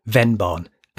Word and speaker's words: Vennbahn: Oh, and thanks also to Twitter's RevChips for sending me a link about the Venbon Trail, which, by the way, is Venbon. As in V-E-N-N, Bahn. It means Fenway or Venway in German, but it's pronounced with an F.